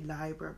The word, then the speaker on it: Libra: Mind you, we know to say Libra because that's from library and it's the proper Latin pronunciation.